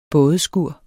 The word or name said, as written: bådeskur